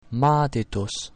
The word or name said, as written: madidus